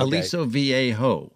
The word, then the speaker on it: Aliso Viejo: I think it's going to say Aliso Viejo, but I don't know if I'm saying it right. – Well, it's close. Okay. Aliso Viejo. Viejo.